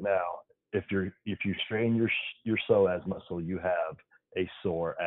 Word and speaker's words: psoas: The psoas muscle.